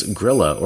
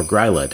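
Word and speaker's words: Grýla: Number four is Grilla or Gryla, depending on which pronunciation you're looking at.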